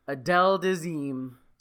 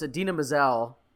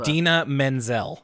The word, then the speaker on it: Idina Menzel: I knew he got the name wrong, but I forgot like just how wrong he gets the name. For those who don't know, it's Idina Menzel. Idina Menzel? Now I'm doing it. Now I'm Travolta. – Idina Menzel. – Idina Menzel, thank you.